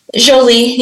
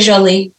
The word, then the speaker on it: Jolie: So, how do I pronounce your name like in French, like Jolie? – Sure, yeah. – Jolie. Is that how you pronounce it? – Jolie. Yeah, Jolie. I say Jolie.